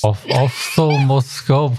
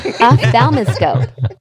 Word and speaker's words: ophthalmoscope: Ophthalmoscope. Hold on. – I do like one of those TikTok voices saying it and then go on. – Optoscope. – Ophthalmoscope.